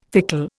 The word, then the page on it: fickle: Indian